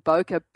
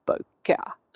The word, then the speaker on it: bokeh: as in B-O-K-E, and he added the H so that people would know how to pronounce the word. So he actually coined the phrase bokeh, or it's bokeh or bokeh.